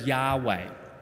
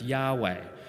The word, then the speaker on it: Yahweh: In Hebrew, It's pronounced as Yahweh. So I am who I am in Hebrew is pronounced Yahweh.